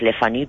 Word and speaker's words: Le Fanu: Yo lo pronuncio siempre a lo francés porque de origen es francés, Le Fanu, pero él es inglés.